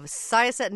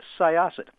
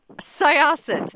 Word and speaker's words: Syosset: Thank you very much, and it's Syosset. – Syosset. – That's okay. It's mispronounced every time I speak to somebody out of the area.